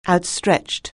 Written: outstretched

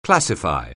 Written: classify

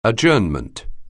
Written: adjournment

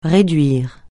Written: réduire

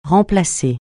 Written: remplacer

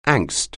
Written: angst

